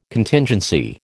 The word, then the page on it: Contingency: American English